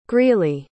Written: greeley

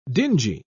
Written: dingy

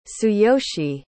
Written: tsuyoshi

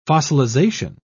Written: fossilization

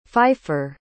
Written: pfeiffer

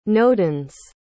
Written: nodens

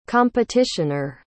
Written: competitioner

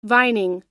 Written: vining

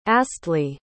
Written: astley

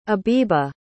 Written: abiba

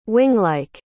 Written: winglike

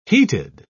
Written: heated

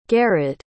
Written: garud

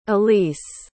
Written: elise

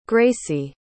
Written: gracy

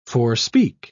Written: forespeak

